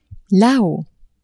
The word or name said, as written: la-haut